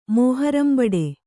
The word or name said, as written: ಮೋಹರಂಬಡೆ